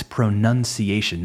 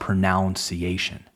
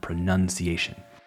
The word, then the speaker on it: pronunciation: Also, Remember, it's pronunciation, not pronunciation. The verb is to pronounce, yes, but the noun is pronunciation. Nun como monja, nun pronunciation.